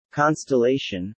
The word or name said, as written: constellation